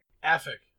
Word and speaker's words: AFICK: AFIC.